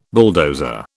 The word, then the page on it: bulldozer: British English